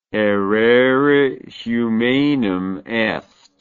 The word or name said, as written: errare humanum est